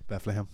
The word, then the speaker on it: Bethlehem: Bethlehem.